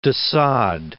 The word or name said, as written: Sade, de